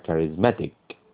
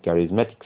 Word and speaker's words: charismatic: charismatic السي الأخيرة دي تنطق والسي الأولى تنطق كي a charismatic